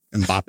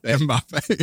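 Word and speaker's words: Mbappé: They don't feel, except for the how do you say the soccer player's name? Mbappe. – Mbappe.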